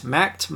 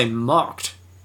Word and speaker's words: Macht: Macht? Marked? Mocked? I only say mocked because it's spelt like yacht, but with an M. it's probably Macht. We're going to say Macht.